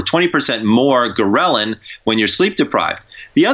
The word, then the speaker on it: ghrelin: One is called ghrelin, and that's spelled G-H-R-E-L-I-N. And the reason I spell it out is because it starts with a G, so I call it the go hormone.